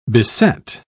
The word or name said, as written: beset